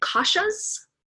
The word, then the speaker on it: kosha: And one of the things that I read in your book was about, and I don't know if I'm pronouncing this right, is it koshas? – Kosha. – Koshas. Okay.